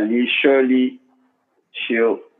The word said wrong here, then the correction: Chisholm: bro. I'm not even gonna lie, this name is hard to pronounce. Shirley Chisholm. So that's Chisholm. Shirley Chisholm, right? – Yeah.